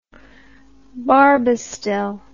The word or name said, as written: barbastel